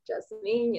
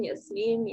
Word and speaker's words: Yasmine: And a lot of people are always like asking me how to pronounce my name, but my parents don't even agree on how to pronounce my name. So Jasmine, Yasmine, Yasmin, etc. all work because I don't know. I don't really know what it's supposed to be.